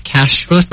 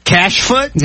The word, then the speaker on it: kashrut: You familiar with kashrut? – Sorry, one more time. – Am I saying it right? – Kashfoot?